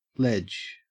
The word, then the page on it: ledge: Australian English